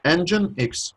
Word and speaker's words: Nginx: Nginx. Like a car engine, and then X. – Yeah, and at any rate, he tweeted about a blog post that they put up about a Groovy handler for Nginx.